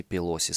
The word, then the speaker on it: Пелоси: Мы в новости ошибочно ударение делали на первый слог Пелоси. Естественно, Нэнси Пелоси, спикер Палаты представителей Конгресса США.